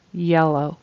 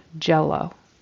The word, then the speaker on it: yellow: Also the word yellow, the color yellow, it needs to start with a Y sound, where your tongue goes all the way in the back, but doesn't really touch anything. Yeah, yellow. And it sounded like you pronounce it with a J, jello. That's something you might eat, a little dessert, jello. And jello, the J sound, your tongue actually pushes off against the top of your mouth.